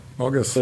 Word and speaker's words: Orges: Ich glaube, er heisst Orges. – Orges? – Tönt auch. Tönt. Gut, Orges. – Ja, Orges.